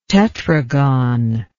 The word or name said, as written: tetragon